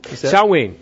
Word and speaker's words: Samhain: Samhain. Samhain is the way it's pronounced. It's spelled Samhain, but you don't pronounce Gaelic by the way it's spelled with English letters. It's Samhain is the way it's said.